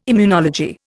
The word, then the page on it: immunology: Indian